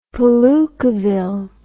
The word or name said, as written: palookaville